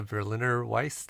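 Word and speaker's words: Berliner Weisse: Berliner Weiss? Berliner Weiss? – Yeah, Berliner Weiss. That's how I would say it.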